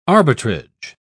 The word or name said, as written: arbitrage